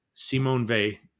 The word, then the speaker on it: Simone Weil: And that's also a really excellent version, because Simone Weil is how you say it. – Okay. – W-E-I-L, Awaiting God.